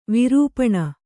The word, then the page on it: ವೀರಪಣ: vīra paṇa